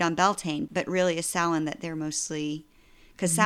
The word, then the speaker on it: Samhain: until Samhain. Is that how you pronounce that? – Samhain.